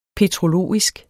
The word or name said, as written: petrologisk